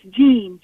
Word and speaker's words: Jean: Am I saying your name right, Teen, like teenager? – It's Jean, J-E-A-N.